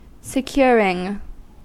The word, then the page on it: securing: American English